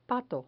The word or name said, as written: pato